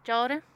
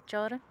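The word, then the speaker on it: ciolim: Jordan. – Jordan? – Yeah, Jordan. – That's produce coordinator teaching me how to say it. – It's C-H-O-L-I-M, right? – No, it's C-I-O-L-I-M.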